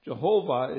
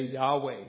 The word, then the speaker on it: Jehovah: Jehovah is the name we use to refer to as our covenant-keeping God. Sometimes you'll hear somebody say Yahweh. It's a more modern pronunciation.